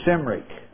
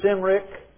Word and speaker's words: Cymric: You have two options, with a soft sound, pronounce it Cymric or Cymric. Both of them allowed. The most ancient being Cymric, as the most ancient pronunciation of Celt is Celt.